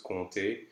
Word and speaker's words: compter: In this case, it is silent, so it's compter.